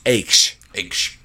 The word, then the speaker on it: Aix: It's AIX, which is pronounced AIX, which is really pissing me off and should piss you off too.